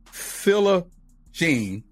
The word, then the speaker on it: Philogene: A warm welcome to Mr. Maurice Philogene.